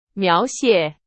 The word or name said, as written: 描写